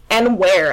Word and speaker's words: Enver: I'm a big Enver... I can't say his name. – Well, I look at it, it was like, it's Enware, actually.